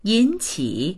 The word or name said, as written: yin3qi3